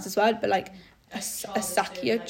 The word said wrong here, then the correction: acacia: trees. It's like A-C-A-C-I-A. Do you know how to pronounce it? – Acacia. – Acacia. Okay, fine. Acacia trees.